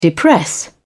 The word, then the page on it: depress: British English